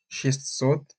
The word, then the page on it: шестьсот: šestʹsot